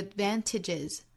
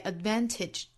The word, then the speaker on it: advantage: haven't had the advantages, 在 advantage, 中间的这个的的发音在这个位置一定要憋一下哈，不是 advantage, 而是 advantage.